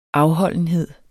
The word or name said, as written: afholdenhed